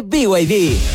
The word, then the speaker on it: BYD: Los VIP. – ¿VIP? ¿No es VIP? – Nada que ver, se escribe BYD, pero se dice BYD, la marca número uno de electromovilidad en Chile y el mundo.